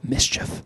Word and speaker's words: MSCHF: So some workplaces encourage employees to donate blood as an act of charity, but six workers at MSCHF, that is, I think you're supposed to pronounce it, Mischief,